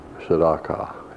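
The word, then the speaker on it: tsedaqah: Jehovah Tzedakah. It's T-S-E-D-A-Q-A-H. Pronounced as said, T-S-E-D-A-W, call, K-A-W, all call.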